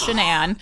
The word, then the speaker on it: Shanann: He murdered his wife, Shanann, and it is pronounced Shanann.